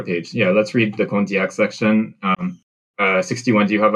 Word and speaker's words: Condillac: It's pronounced Condillac.